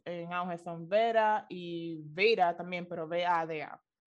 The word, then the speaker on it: Veda: Oh, it is, is Veda. V-E-D-A. – yeah, Veda. That's mm-hmm. how they, according to what I just looked up, it, that's how it's pronounced.